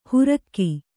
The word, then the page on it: ಹುರಕ್ಕಿ: hurakki